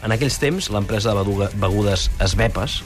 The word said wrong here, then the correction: Schweppes: Perdó? – Schweppes.